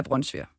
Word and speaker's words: brunsviger: Så nu kan jeg jo lige komme til hele Danmark lige fortælle, at man udtaler det altså brunsviger. – Jeg beklager. – Ja. Men altså, brunsovs er jo også godt, men det kommer jo ikke op på i nærheden af brunsviger.